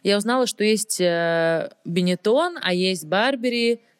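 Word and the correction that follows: Burberry: Бербери, как правильно говорит Настя? Бербери.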